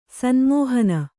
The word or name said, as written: ಸನ್ಮೋಹನ